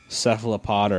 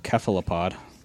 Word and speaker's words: cephalopod: So in Greek, the C is pronounced, it's always a hard C. There's no soft C sound in Greek. – Yeah. – So, you know, Caesar is not Caesar, it's Kaiser. So technically, cephalopod is correct, except for, like, everybody says cephalopod, so.